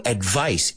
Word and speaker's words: advice: They give you advice. Y fijaos, to advise es el verbo. Suena como una Z, pura y dura. And advice, el sustantivo, que se escribe con una C, suena como una S.